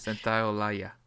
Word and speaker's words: Santaolalla: Santaolalla.